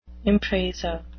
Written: impresa

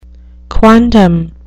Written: quondam

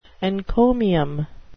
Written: encomium

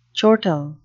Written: chortle

